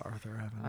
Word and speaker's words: Arthur Avenue: It's pronounced Arthur Avenue.